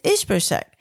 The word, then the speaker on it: Ishbosheth: And I hope I'm saying it right in English, Ishbosheth. We call him Ishbosheth because that's how we say it in Spanish. But the teacher basically said, it's Ishbosheth. And she was like, yes, I know that because I've learned about the kings.